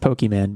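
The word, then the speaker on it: Pokémon: It's pronounced Pokemon, but continue.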